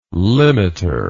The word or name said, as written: limiter